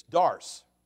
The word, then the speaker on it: Doris: C-O-O-P-E-R. Now, the fact is, that's not how we say it where we come from. It's Dars. Dars. That's how you say it. It's D-O-R-I-S, but Dars.